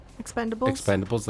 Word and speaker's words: Expendables: And like, kind of like that Expandables. – Expendables? – Did I say it wrong again? – Yes.